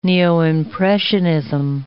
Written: neo-impressionism

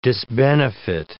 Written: disbenefit

